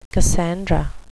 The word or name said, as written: cassandra